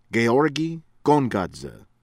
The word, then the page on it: ABASHIDZE, ASLAN: AHS- lahn ah-bah -SHEED- zeh